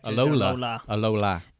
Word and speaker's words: Alola: For those of you who don't know, the region of Alola. I'm saying that right? – Alola.